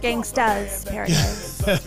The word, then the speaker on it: Gangsta's Paradise: and the song is Gangster's Paradise. – Gangsta's Paradise. There's you. – Not, you gotta say it right.